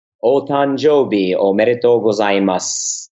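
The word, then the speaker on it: Otanjobi omedeto gozaimasu (お誕生日おめでとうございます): Maybe you can say it properly. – Otanjobi omedeto gozaimasu. Nicely done.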